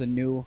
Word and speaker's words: Aknu: So, of course, my name is Anu. If you want to look us up, it's A-K-N-U. That's how you spell it.